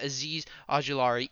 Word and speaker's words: Azeez Ojulari: They got Azeez Ojulari even after trading down.